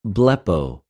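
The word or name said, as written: βλεπω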